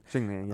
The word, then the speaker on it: Jingliang: Jingliang, yeah.